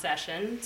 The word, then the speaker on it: Saison: So if you ever see a beer and the word on the box is going to look like Saison, that's actually pronounced Session.